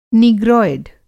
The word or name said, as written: नीग्रोयड